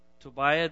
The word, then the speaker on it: Tobiah: When Sanballat the Horonite and Tobiah the Ammonite official, let me put the accent on the right syllable, Tobiah the Ammonite official heard about this,